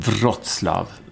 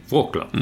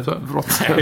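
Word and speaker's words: Wrocław: Uttalas det så? – Mm. Vad trodde du det var? – Wroclaw. – Mm. Ja, men det är det inte. Wroclaw. – Nej, okej. Jag har alltid sagt Wroclaw.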